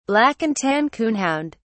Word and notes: black-and-tan coonhound: British English

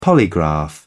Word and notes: polygraph: British English

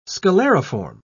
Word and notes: scalariform: British English

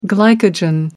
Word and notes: glycogen: American English

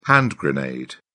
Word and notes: hand grenade: British English

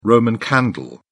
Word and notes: roman candle: British English

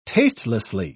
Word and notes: tastelessly: British English

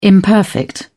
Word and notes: imperfect: British English

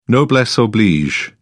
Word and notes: noblesse oblige: British English

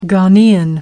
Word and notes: Ghanaian: American English

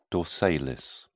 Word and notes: dorsalis: British English

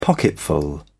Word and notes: pocketful: British English

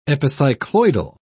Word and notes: epicycloidal: British English

